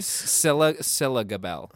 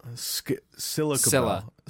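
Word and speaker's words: Scilla Gabel: Scylla Gabel. – Scylla Gabel. Scylla. Yeah, like Priscilla.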